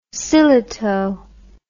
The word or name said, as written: sillitoe